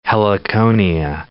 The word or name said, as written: heliconia